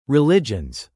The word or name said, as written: religions